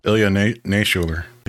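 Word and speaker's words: Ilya Naishuller: This is also directed by Hardcore Henry Helmer, Lila Nashuler. Is that how you say it? – Ilya Nashuler.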